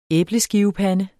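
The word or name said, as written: ÆBLESKIVEPANDE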